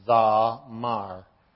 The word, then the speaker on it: Zamar: It's basically pronounced just like it looks, pretty much, Zamar.